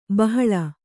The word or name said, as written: ಬಹಲ